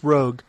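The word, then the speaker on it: Roeg: How do you say his last name? – Nicholas Rogue.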